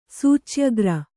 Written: ಸೂಚ್ಯಗ್ರ

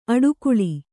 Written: ಅಟ್ಟುಗೂಳು

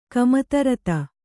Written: ಕಾಮಿತಾರ್ಥ

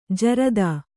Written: ಜೋರತ್ತು

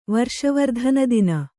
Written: ವರ್ಷವರ್ಧನದಿನ

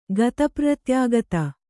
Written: ಗತಪ್ರ್ರತ್ಯಾಗತ

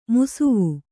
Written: ಮುಸುವ

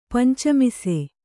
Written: ಪಂಚಮಿಸೆ

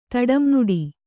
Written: ತಡಂನುಡಿ

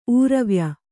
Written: ಊರವ್ಯ

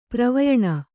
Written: ಪ್ರವಯಣ

